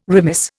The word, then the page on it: remiss: Indian